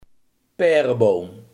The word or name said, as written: perenboom